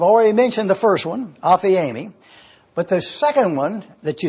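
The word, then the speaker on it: Aphiemi (ἀφίημι): It is A-P-H-I-E-M-I. It's pronounced Aphiemi. Aphiemi.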